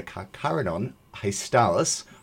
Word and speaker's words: Carcharodon hastalis: I did find a Carcharodon hastalis.